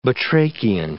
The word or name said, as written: batrachian